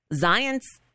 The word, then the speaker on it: Zients: His replacement is a guy named Jeff Zients. Zients rhymes with science.